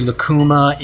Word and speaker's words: Lacuma: Now, way back in 2001, you founded an operation called Lacuma Films. Did I say it properly? – You said it properly. Lacuma is a Peruvian fruit.